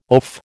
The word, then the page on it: oaf: American English